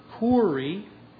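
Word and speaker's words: kuri (κύριε): And I said unto him, sir, and that's the Greek word kuri. We would spell it K-U-R-I-E, like kurios for Lord, but this is...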